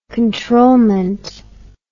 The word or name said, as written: controlment